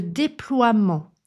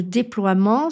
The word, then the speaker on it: déploiement: Je pense au nom « le déploiement » qui vient du verbe « déployer ». Le déploiement s'écrit D-E-P-L-O-I-E-M-E-N-T. Le E est muet.